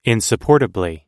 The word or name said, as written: insupportably